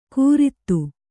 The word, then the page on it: ಕೂರಿತು: Kannada